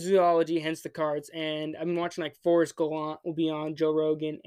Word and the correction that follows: Galante: Or Galante is actually pronounced his name.